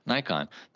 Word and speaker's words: Nikon: But their marketing to Americans said Nikon. The president of Nikon North America in interviews, he says Nikon. So the reason Americans say Nikon is because Nikon said Nikon to Americans.